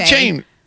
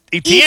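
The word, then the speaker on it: Achane: And so apparently, A-Chain, who was formerly, the artist formerly known as ETN, says that it's always actually been A-Chain, but we've always been pronouncing it wrong with ETN.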